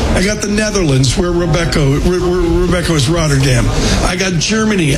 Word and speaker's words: Robeco: Depends if you're Dutch. It's Robeco.